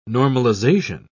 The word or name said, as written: normalization